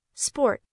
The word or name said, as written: sport